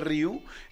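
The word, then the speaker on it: Rieu: La gente que conoce a André Ryu eh,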